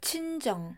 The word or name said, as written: 친정